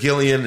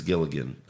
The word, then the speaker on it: Gilligan: You guys pronounce it Gillian. It's actually Vince Gilligan.